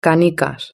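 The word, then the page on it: canicas: European Spanish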